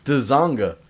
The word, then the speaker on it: Dzanga: Dzanga. There's a D in there, like a football player. – No, you don't pronounce the D. The D is silent, like Django. It's Dzanga.